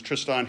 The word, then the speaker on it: Tristan: It's not Tristan, it's Tristan Harris.